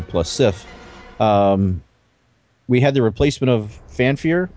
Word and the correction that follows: Fandral: Did I pronounce that right? – Fandral.